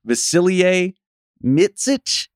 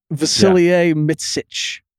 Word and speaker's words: Vasilije Micić: I believe it's Vasilije Micić. – It seems like that's what the EuroLeague announcers are saying. – Yeah. – Vasilije Micić.